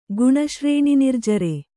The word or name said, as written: ಗುಣಶ್ರೇಣಿನಿರ್ಜರೆ